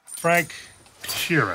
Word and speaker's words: Sheeran: Frank Sheeran. Am I saying that right? – Yeah, you said it right.